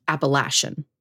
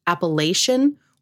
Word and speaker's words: Appalachian: After digging a little deeper, I learned that it can be pronounced Appalachian or Appalachian. – Okay. – Lay or latch being the difference, depending on the region.